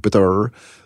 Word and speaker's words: Butter: En dat is in 2012, heb ik samen met een Nederlandse journalist, Jan Kees Butter, zo moet je het uitspreken, Butter, een boek gemaakt, Het Foute Elftal, waarin we veel van die verhalen hebben samengebracht.